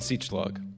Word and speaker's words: Siedschlag: Jason Siechlog. – Really? How do you spell that? – S-I-E-D-S-C-H-L-A-G.